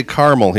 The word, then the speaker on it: Carmel: Well, I think for the people that are listening outside of Indiana, the Carmel is, and it's spelled just like the city in California called Carmel, but we say Carmel here in Indiana.